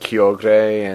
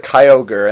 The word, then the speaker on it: Kyogre: I hear it pronounced both ways. – See, I always called it Kyogre, and then like they actually went and said like Kyogre, and it's like, well, that's I guess the Japanese one, but what?